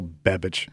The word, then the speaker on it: Babich: Babich. No, we're saying it the right way. Yeah, it just sounds like it's spelled like bitch on the end, right? – Yes, you know?